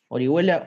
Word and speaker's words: Orihuela: Eh, Twitter, Tomás Orihuela con doble T, Instagram, Tomás Orihuela con doble T. Orihuela, Ori H-U-E-L-A,